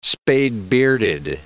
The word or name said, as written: spade-bearded